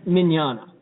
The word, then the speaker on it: Mignogna: You look at Mignogna. – Yeah. – And it does not look the way it sounds.